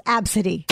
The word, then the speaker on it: Abcde: no one's ever going to look at that a b c d e and know that it's pronounced absidy